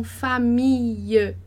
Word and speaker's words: famille: Então, a gente não fala famille, a gente fala família, família. Com o E fechado.